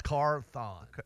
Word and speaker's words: Carthon: No, it's Carthon. Like Marathon Carthon.